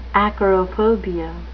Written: acarophobia

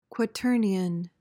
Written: quaternion

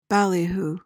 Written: ballyhoo

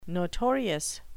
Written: notorious

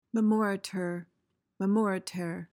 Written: memoriter

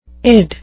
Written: id

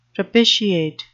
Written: propitiate

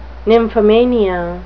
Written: nymphomania

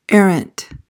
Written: errant

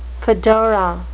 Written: fedora

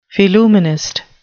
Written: phillumenist

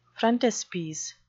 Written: frontispiece

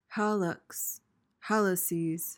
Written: hallux

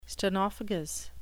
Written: stenophagous

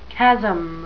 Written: chasm

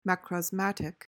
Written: macrosmatic

